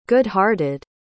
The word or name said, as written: good-hearted